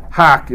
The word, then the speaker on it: hough: Now, to hock is how that's pronounced, H-O-U-G-H.